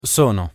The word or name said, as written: sono